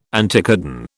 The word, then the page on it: anticodon: British English